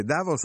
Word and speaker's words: Davos: sulla, sulla, sulla O, cioè l'accento va sulla O, Davos. Gli italiani dicono tutti Davos, non so perché, però è, è Davos.